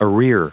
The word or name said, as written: arrear